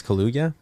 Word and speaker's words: Kaluuya: I think it's Kaluuya.